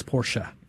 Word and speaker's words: Porsche: Say Porsche, it's not Porsche, it's Porsche.